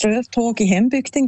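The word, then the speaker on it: Strövtåg i hembygden: Ich finde, du hast die Aussprache super hergebracht. Das Lied heisst Ströftag in Hembygden, genau.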